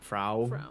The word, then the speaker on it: Frau: I'm going by like how they pronounce it in the film, which is Frau.